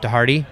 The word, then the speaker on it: DeHarde: All right, everybody, I'm sitting here at Portland International Raceway with Christopher DeHarde. I said that right, right? – Oh, yeah. – Yes! – You got that right.